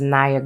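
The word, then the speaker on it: Nhayah: Again, my name is Naya Good, and you can spell that N as in November, H as in hotel, A as in alpha, Y as in Yankee, A as in alpha, H as in hotel.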